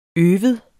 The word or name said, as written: øve